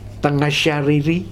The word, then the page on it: Tagajariri: Futuna